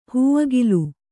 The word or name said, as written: ಹೂಅಗಿಲು